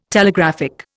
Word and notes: Telegraphic: Indian

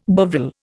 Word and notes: Bovril: Indian

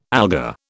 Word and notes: alga: British English

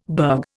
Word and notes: burg: Indian

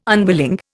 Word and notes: unwilling: Indian

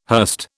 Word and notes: hurst: British English

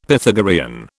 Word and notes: Pythagorean: British English

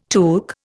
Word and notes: took: Indian

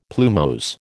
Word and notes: plumose: American English

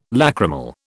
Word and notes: lachrymal: British English

